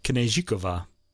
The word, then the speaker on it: Kněžíková: Kateřina Kněžíková. And you pronounce the K, Kněžíková.